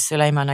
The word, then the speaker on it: Suleyman: I never used to pronounce it as Suleiman. I always used to pronounce it where it was slightly closer to Solomon almost.